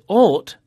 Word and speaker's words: ought: Now, the words thought, spelt T-H-O-U-G-H-T, and ought, spelt O-U-G-H-T, both have the O-U-G-H pattern in them, and it's pronounced aw. Thought. Ought. – Thought. Ought. – Mm. – And there are more O-U-G-H verbs with the same pronunciation, like fought, the past of fight, and bought, the past of buy.